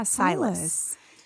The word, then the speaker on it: Silas: Is that how you say it? S-I-L-A-S. – Yeah, Silas.